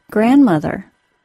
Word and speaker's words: grandmother: I was asked if I say the D sound in the middle of the word grandmother. No, I don't, and I was impressed that this student had caught the pronunciation.